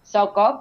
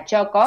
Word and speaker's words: Txoko: También eso fue un desafío acá en Córdoba, cuando abrió este lugar de comida vasca que se llama Choco, se escribe T-X-O-K-O, Choco, pero bueno, se pronuncia Choco.